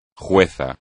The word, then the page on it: juez: espagnol